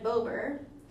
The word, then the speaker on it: Bober: Um, she tried to warn them about the property, but her father, Raymond Bober, that's how it's pronounced, it looks like Bobber, basically, mm-hmm. but with, no, with only like two B's, um, had made the decision to just buy it.